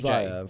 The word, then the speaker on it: Xiav: His name's Ziav.